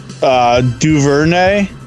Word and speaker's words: Duvernay: Higgins versus Duvernay. I don't know how to pronounce that. – No, you said it right.